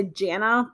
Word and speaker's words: Jayna: The first one is, it was pronounced two different ways, but it's spelled J-A-Y-N-A. So Jayna. – Okay. – A lot of them say Jana, but I want to say Jayna. I think that sounds more correct.